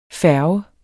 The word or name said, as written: færge